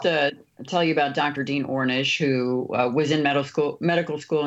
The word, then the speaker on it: Ornish: My question for you, the Amish, I hope I'm pronouncing that right, Amish or Amish? – Ornish, thank you.